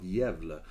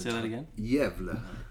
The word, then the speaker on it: Gävle: And we were living in a tiny little northern town in Sweden called Gävle. And we lived in Gävle. Like it's spelled G-A with two dots, V-L-E.